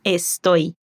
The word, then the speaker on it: estoy: La chica también aspira la S en estoy.